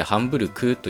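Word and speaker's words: Hamburg: 例えばドイツの都市でハンブルクというのがありますけど、このハンブルクというのも、綴りの上では G で書くんですけど、発音としてはクという無声音になってるんですね。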